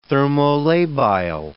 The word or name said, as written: thermolabile